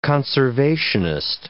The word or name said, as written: conservationist